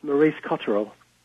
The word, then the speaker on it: Maurice Cotterell: It's Maurice Cotterell.